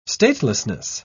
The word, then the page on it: statelessness: British English